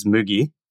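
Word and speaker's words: mugi (麦): Barley is mugi.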